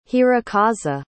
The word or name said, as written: hirokazu